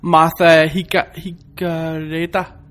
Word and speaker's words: Martha Higareda: Der er en pige, som hedder Martha Higareda. – Ja. – Jeg ved ikke, hvordan man udtaler. – Nej, det lyder rigtigt.